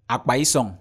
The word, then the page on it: akpaisong: Efik